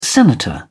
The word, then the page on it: senator: British English